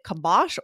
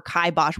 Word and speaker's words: kibosh: I hear both for sure. So I had to look up in the dictionary. They'll give you like standard pronunciation, which was kibosh, because I do hear kibosh as well. You guys might hear that. Put the kibosh on. – Yeah. – So it's just funny. It's so different. That's the same thing, just variants of the pronunciation.